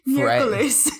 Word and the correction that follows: Mercury: Just Mercury.